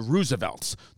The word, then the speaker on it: RSVLTS: And by the way, I realize I just did that whole ad saying results, and it's the Roosevelts. The way you say that shirt company is the Roosevelts, but sometimes it looks like an E because they spell it RSVLTS, and I'm an idiot, and I'm dyslexic.